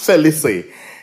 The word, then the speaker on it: Felicien: It's Felicien.